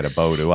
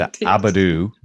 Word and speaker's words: Abodoo: And you'll notice, by the way, I got the pronunciation of that word right, Abodu. I had said Abadu before, thinking of the Flintstones.